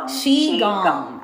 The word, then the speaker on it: qigong: Qigong.